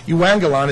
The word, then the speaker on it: euangelion: Euangelion is actually how you pronounce it.